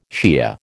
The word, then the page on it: Shiah: American English